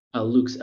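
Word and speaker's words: Loox: Luke's, L-O-O-X.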